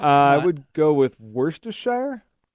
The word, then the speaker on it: Worcestershire: but they said that the last part of it, the S-H-I-R-E, Shire, in the English pronunciation, the way the British do, is it's just the word sure. – Sure. – Like in New Hampshire. – Okay. – So Worcestershire. So Worcestershire sauce.